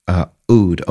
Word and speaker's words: Oud: Oud, O-U-D, Oud is how it's pronounced, but you don't have to say Oud. You can say Oud, O-U-D.